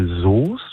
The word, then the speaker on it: Soest: Da wäre ich bei Soest. Schreibt sich ja mit OE, weil man spricht das O aus.